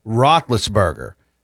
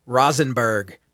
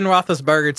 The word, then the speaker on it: Roethlisberger: And I say that jokingly because my dad, Big L, he was never able to pronounce Roethlisberger. It always came out Rosenberg. Every time he referenced Ben Roethlisberger to me, it came out Rosenberg.